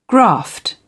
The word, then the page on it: graft: British English